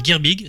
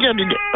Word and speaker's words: Girbig: Alors, la musique, c'est Julien Girbig C'est comme ça qu'on prononce Oui. Hein tout à fait, Julien Girbig bien.